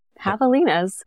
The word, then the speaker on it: javelina: And yeah, javelinas. – The J is silent.